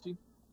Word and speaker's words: Ogeechee: Okay, so let's talk a little more about some of your songs, like Ogeechee? How do you say that? – Ogeechee? – Yeah, okay.